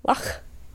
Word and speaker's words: Loch: They say it Loch. – Loch. – The CH is pronounced in a very German way. Loch. – Or Jewish, like a challah.